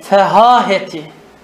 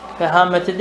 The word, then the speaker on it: fehâhet: İlâ deracetil fehâheti. Fehâmeti değil. Fehâheti.